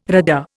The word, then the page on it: RADA: Indian